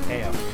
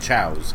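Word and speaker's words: Chao: Yeah, back when I played this game, I thought they were pronounced Kao. – Yeah. – I never knew how they were. Yeah, why is it's the Chaos Emeralds, but the creatures are called Chows, because, you know, that makes sense.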